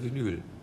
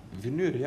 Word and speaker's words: Vinyl: gesagt Vinyl. Vinyl, ja. Vinyl. Das hast du doch richtig gesagt.